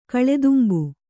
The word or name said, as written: ಕಳೆದುಂಬು